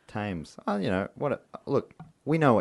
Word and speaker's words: Thames: I fucked up saying it wrong. – That's what I'm... Oh. – Thames.